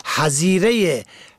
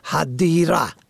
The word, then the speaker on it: حظیره: حظیره گونه نوشتاری حدیره ه دال یا ره، در اصل همون حظیره ح زوات یا ره، یعنی قبر و قبرستان در عربی است که فارسی زبانان در زبان شفاهی همواره ذات آن را دال تلفظ میکنند، حدیره.